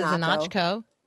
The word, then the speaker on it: Znachko: Linda Znachko. – Znachko. – Uh, mm-hmm. Um, oh, like knock on the door.